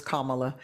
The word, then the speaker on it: Kamala: I want to make sure that we make sure our vice president's name is said correctly. It is Kamala.